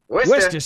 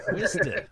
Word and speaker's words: Worcester: And he's like, oh, Worcester. – Worcester Street. And I'm like, no, no, no. There are many more letters in that word than you pronounced. – Worcester.